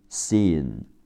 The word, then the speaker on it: scene: 名词场场景 scene s c e n e scene s c 在一起读 s，第一个 e 读 e c e c，字母 n 读 n c n，最后一个 e 不发音 c n scene。